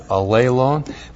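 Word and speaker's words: allelon (ἀλλήλων): So to love one another, because we have the reciprocal pronoun, allelon, looks like that in the Greek, A-L-L. Long E, L, long O, N, allelon.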